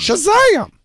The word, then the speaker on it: Shazam: Shazam! – And that's how you pronounce that, correct? – Right, yes.